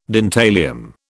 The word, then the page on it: dentalium: British English